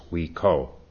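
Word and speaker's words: Huike: Hui Ke, our China pilgrims came back and reported that it should be pronounced Hui Ke.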